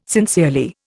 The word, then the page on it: sincerely: Indian